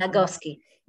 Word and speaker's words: Nagoski: I think one reason I really, really like the book you recommend me, Burn Out by Emily, how to pronounce Nagoski.